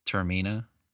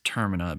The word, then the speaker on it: Termina: Like the land, the world, the city, whatever, Termina. In which that's the correct pronunciation because Termina. – One of the I can't remember who said it, but they pronounce it for us. But some people think it's Termina because it's like terminal for like something ending or something.